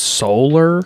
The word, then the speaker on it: Solaire: It's pronounced solar.